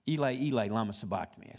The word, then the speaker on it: Eli, Eli, lama sabachthani: When Jesus Christ was on the cross just before He died, He said, Lama, lama, sabachthani, Ela sabachthani, which means, my God, why hast thou forsaken me? Which is one of the Psalms. Eli, Eli, lama sabachthani, I said it wrong.